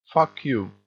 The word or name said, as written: fuck you